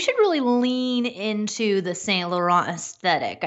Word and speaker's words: Saint Laurent: I got some boots from Sarah from Saint Laurent. Am I saying that right? – Sarah nailed it.